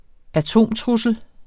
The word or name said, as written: atomtrussel